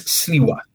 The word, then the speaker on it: Sliwa: Rabbi Kiedman has sent us a message saying he's enjoying the show and that the Republican is Curtis Sliwa. I mispronounced the name, so thank you so much, Rabbi, for sending me that.